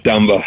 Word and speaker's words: Dumba: How do we say the last name again? Matt Dumba. Thank you.